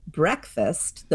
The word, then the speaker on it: breakfast: This is why we call the first meal of the day breakfast. The breck is spelled like the word break, and that is indeed what it means.